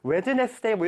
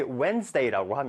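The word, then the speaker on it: Wednesday: 우리 왠지 웨드네스데이 뭐 이렇게 읽어야 될것 같은데 우리 웬즈데이라고 합니다. 자, 그래서 우리 오른쪽에 설명을 보면 D를 발음하지 않으니 유의하세요라고 되어 있죠.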